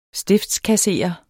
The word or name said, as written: stiftskasserer